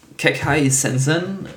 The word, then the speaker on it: Kekkai Sensen: Kekkai Sensen, richtig ausgesprochen? – Ja.